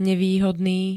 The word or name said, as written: nevýhodný